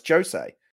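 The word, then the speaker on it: José: I spent years thinking I was being clever by saying Jose Mourinho, and I found out I eventually said, no, it's Jose, which is weird. Like you did the last thing you think you'd be pronouncing as a J sound, but it's actually pronounced Jose Mourinho.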